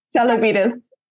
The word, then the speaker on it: Tselebidis: It's Tselebidis. – Tselebidis. – I know. Everyone gets it wrong. – Yeah, there's a T and an S. It's a silent T.